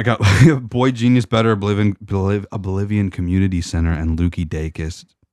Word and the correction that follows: Lucy Dacus: I said that right. No, I didn't. Lucy. – Yeah, Lucy Dacus.